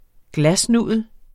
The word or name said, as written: glasnudel